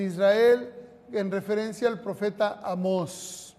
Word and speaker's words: Amós: No, Amós, tiene acento en la O.